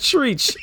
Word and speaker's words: Treach: T-R-E-A-C-H.